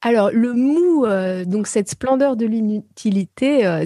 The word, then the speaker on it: mu: Vous évoquez la notion, je ne sais pas si c'est la notion ou plutôt un concept, le mu ou le mou, je ne sais pas comment ça se dit. – Le mou. – Voilà, M-U, qu'on dit mou. – On se prononce mou en japonais, voilà.